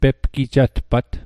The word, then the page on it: pepgijatpat: Mi'gmaq